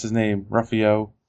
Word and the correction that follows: Ruffalo: Ruffalo. – Ruffalo. There's an L in there. I always remember that. It's Buffalo with an R.